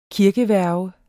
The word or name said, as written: kirkeværge